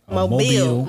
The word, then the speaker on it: Mobile: That's not how you say it. Oh, okay. It's Mobile. – Alabama. – Mobile.